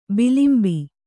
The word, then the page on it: ಬಿಲಿಂಬಿ: bilimbi